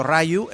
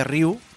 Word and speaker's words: Ryu: Lo cual eh, sirve para que a día de hoy mucha gente en Estados Unidos siga diciendo Rayu en vez de Ryu a, Ryu de Street Fighter 2.